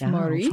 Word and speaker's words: Maurice: So the area I'm in is French. So it's Maurice. – Français. – And even they would say I'm not saying it right. I should really get my husband up here to say it. But outside of this area, a lot of people will say Morris and things like that.